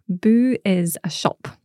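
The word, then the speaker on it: bùth: B-U-T-H with an accent on the U, so nice long oo, boo. – So that's a silent T-H at the end. – It is, yeah.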